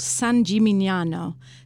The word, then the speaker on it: San Gimignano: It's called, now please forgive me if I mispronounce this, it's called San Gimignano. Oh, that's quite good, actually.